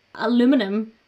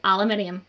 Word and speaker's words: aluminium: all Americans say aluminum when it is spelt aluminium. I didn't think about that. – That's how. No, aluminum. – No, there's an I after the N at the end of it. It's aluminium.